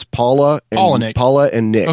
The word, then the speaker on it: Palahniuk: So, like, I'm reading this story, and apparently the writer, Chuck, I can't pronounce his name, Palahniuk. – I've heard him describe it. It's very easy to say. It's like a woman's name and a man's name. It's Paula and Nick.